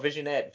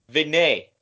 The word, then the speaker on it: vignette: What was the word I couldn't say? Oh, visionette. Visionette. – Oh, God. So what is it? What's the proper way to say it? – Vignette. – Vignette. – Vignette. Okay.